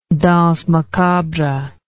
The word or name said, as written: danse macabre